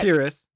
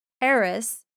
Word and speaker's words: Pearis: It was named after a man named Richard Pearis, but his name was spelled pear, like P E A R, like the fruit, and then I S. So, you pronounce it Pyrrhus or something, but Pyrrhus.